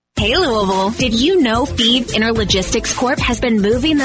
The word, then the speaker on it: Fives: Thebes is spelled F-I-V-E-S, like the number, but pronounced Thebes.